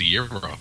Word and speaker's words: gyro: Apparently it's pronounced a gyro.